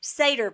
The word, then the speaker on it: Seder: They call this a Seder plate, S-E-D-E-R. Seder is how you pronounce it.